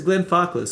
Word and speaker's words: Glenfarclas: I know how to say it, like a Glen, basically a Glen, you know, it's a Glen Farkless.